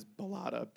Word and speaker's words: Bilotta: My last name is Bilotta, B-I-L-O-T-T-A, but we say it like it's a D at the end, and I had, like, Bilotta. – Right. – And that's the, like, New England accent.